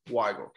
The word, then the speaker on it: Weigel: Is it Weigel or Weigel? – Weigel.